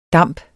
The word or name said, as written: damp